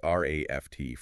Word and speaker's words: RAFT: RAFT framework. – Right. And that ACE is pronounced simply as the word raft.